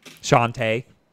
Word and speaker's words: Shantae: And time to play Shanty 3. I've actually never played a Shanty game. Shantae. Is that how you say it? – The SN 30 Pro.